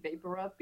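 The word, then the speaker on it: VapoRub: You know what I had to get used to that name in English, Vic Vapor Up, because like in Venezuela, we pronounce everything the wrong way. You know, all these English names. And Vic Vapor Up was Vic Vaporu.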